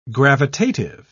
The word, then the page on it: gravitative: British English